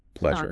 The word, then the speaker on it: pleasure: That soft S sound, I've been confused. It's similar to the S in measure or treasure, pleasure.